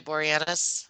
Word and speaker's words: Boreanaz: So far, David, is that how you say it, Boreanaz? – Uh-huh.